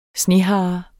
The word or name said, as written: snehare